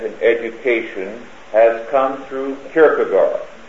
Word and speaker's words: Kierkegaard: K-I-E-R-G-E. K-I-E-R-G-E. K-I-E-R-K-E, excuse me, G-A-A-R-D. Kierkegaard, it sounds, but Kierkegaard pronounced.